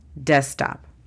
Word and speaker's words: desktop: La pronunciación no cambia. D-E-S-K-T-A-P, desktop. Laptop. – Computadora portátil. – La pronunciación fonética es L-A-P-T-A-P, laptop.